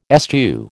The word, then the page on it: eschew: American English